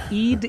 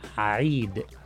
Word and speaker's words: Eid: It's not Eid. – Tongue. – Okay, you know, Eid. – Come to the classes, you know. Eid is the English pronunciation. In Arabic it is Eid.